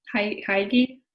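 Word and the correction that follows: hygge: I don't know if I'm saying that right. – Like a Dutch oven? – No, that Heidi, like the Dutch, I think. – Oh, Huga. Yeah. – I knew, yes. I was not saying it right.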